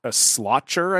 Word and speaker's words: sliotar: Yeah, it's called like a slotcher, I think is how you pronounce it. It's spelled S-L-I-O-T-A-R.